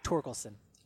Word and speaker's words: Torkelson: In fact, we didn't even have lines on guys like Spencer Tolkinson. Get into that right on the pronunciation? – We're getting close on the pronunciation guy. Torkelson.